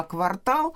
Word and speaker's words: квартал: Всегда квартал э, в каждом из этих значений, в обоих значениях, произносится с ударением на второй слог.